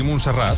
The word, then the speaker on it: Montserrat: ¿Lo he dicho bien? ¿Montserrat? – Sí, Montserrat.